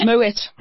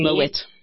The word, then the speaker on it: Moët: But no, a lot of people think that they must pronounce it the French way and go Moe. – Moe. – But meanwhile, the family. – It's actually Moet. – Yeah, are originally from Holland, from the Netherlands.